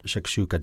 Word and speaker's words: shakshuka: If you haven't had shakshuka, did I say it right? – Yeah, yeah.